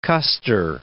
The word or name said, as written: Custer